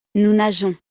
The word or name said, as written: nous nageons